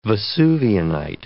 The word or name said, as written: vesuvianite